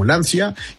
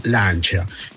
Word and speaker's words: Lancia: Lancia, una de las marcas que poco sabemos en México, se escribe Lancia, algunos los pronuncian como Lancia y algunos como en italiano Lancia.